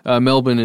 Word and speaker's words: Melbourne: Melbourne is probably closer to what that would be, but you pronounced it correctly.